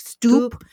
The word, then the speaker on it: Stuppe: And actually, even in this particular pamphlet, his name is spelled S-T-U-P-P-E. So it's more like Stoop.